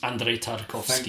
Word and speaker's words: Andrei Tarkovsky: Andrei Tarkovsky.